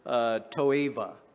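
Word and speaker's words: toeva (תועבה): Abomination is from the word toeva in the Hebrew. It's spelled with a B, but it has a V in pronunciation.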